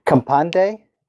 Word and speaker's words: kaMpande: Campande.